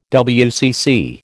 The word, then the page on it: WCC: American English